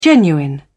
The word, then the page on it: genuine: British English